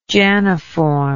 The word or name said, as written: janiform